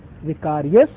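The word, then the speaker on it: Vicarius: अगर आप पोप के सर पे एक ताज होता है ना, है ना, उसमें पता क्या लिखा होता है, विकारियस फिली डी, ये है spelling, V I C A R I U S, विकारियस।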